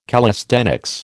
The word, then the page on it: callisthenics: American English